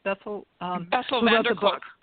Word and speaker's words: Bessel van der Kolk: You had mentioned Dr. I keep pronouncing his name, Besselkopf or something. – Bessel van der Kolk.